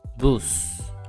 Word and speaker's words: bus: En bus. Listen and repeat. – En bus. En bus. – The spelling of the word bus is the same of the English word bus, but it has a different pronunciation.